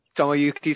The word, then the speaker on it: Tomoyuki: How do you say his first name? – Tomoyuki Sugano. – Yeah, that's good enough.